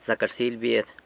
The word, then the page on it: Zac rsily bied: San Lucas Quiaviní